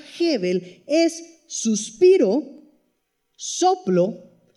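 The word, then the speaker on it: Hebel (הֶבֶל): Y la palabra que utiliza aquí el autor es una palabra que se pronuncia Hebel. Se escribe H-A-B-E-L, Abel, pero en realidad se pronuncia Hebel.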